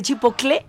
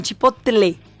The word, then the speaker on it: chipotle: Es como hay gente que dice chipocle y no chipotle. – ¿Y si yo pido un bitle? – ¿Por qué dicen chipocle? Si se dice chipotle, todavía no.